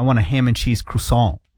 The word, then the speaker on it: croissant: Is it croissant? – Croissant.